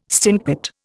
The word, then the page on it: stinkpot: Indian